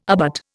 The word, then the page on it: abut: Indian